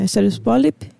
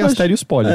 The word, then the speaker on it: Asterios Polyp: E eu recomendo que, se você gostou muito de Asterios Polyp, que eu não sei pronunciar direito. – Eu sempre falei Asterios Polyp, eu acho que é assim mesmo.